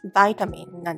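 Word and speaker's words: vitamin: ビタミン C とか、ビタミン D とかですね。あれ、英語発音だと、バイタミンなんですよ。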